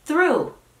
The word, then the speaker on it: through: Ahora es through. ¿Por qué? No tengo la menor idea. Notemos que el sonido de la TH ya ha cambiado y ahora es mucho más suave.